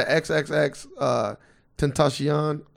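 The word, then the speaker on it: XXXTentacion: Like, what about like I ain't gonna lie to you, when I first heard the XXX Tentacion, that's how you say it, by the way, that psychologically hearing his name, because I'm not like big on X.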